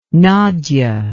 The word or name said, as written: nagy